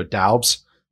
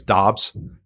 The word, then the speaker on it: Doubs: I always want to say Romeo Doubs, but apparently it's Dobbs, how you pronounce it. I've heard three different pronunciations for it. So I'm just. – I think it is Dobbs. They threw the U in there just to confuse everybody.